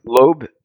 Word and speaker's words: Lobe: Help me out, make sure I'm pronouncing his name correct. So Jonah Loeb, is that right? – Loeb, L-O-B-E.